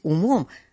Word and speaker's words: умом: И это ангел-хранитель, переставший жить умом, с ударением на «у», спрашивал, все ли у нас благополучно.